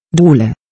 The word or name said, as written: dohle